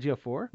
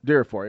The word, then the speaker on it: Ejiofor: Who was Chiwetel Belafor? Is that how you say his name? – Chiwetel Ejiofor. – Ejiofor, there you go.